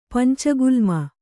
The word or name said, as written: ಪಂಚಗುಲ್ಮ